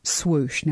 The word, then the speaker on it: Swoosh: Now, we pronounce it slightly differently, and I think it's because you're in Illinois and I'm in Texas, so we have a little bit of a different accent, but S-W-O-O-S-H.